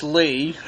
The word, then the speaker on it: Ley: It's spelled L-E-Y, but it's pronounced Lee.